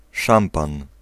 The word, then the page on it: szampan: /ˈʂampan/